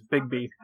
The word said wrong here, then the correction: Begbie: he plays Bigby. It's Robert Carlyle. Begbie.